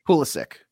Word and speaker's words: Pulisic: Is it Pulisic or Pulisic? – Pulisic. – Pulisic. Uh, he says Pulisic, Sick. but he's he's also Croatian, so it could be Pulisic.